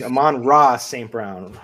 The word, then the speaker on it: Amon-Ra St. Brown: I think you might've forgotten a syllable in there somewhere. – Yeah, there. That's what I think. Amon-Ra St. Brown.